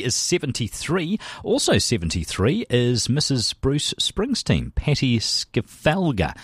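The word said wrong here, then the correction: Scialfa: No, how do you say that? Skyalpha, I wasn't even close.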